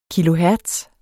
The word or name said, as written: kilohertz